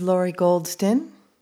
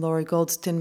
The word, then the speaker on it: Lori Goldston: This is Laurie Goldston. I'm here with Judith Hamann, who's visiting from San Diego and Melbourne, both playing cello. Judith's last name is spelled H-A-M-A-N-N. I'm Laurie Goldston. My name is spelled L-O-R-I-G-O-L-D-S-T-O-N.